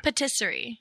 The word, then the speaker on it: Patisserie: Now, how do you say that second word? – Patisserie. – Patisserie.